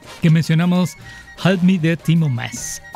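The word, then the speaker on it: Maas: ¿Lo dije bien? ¿Timo Mas? – Yes.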